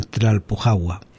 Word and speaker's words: Tlalpujahua: toqué en Tlalpujahua. Uh-huh. No sé si lo digo bien. – Sí. Sí.